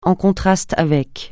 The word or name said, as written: en contraste avec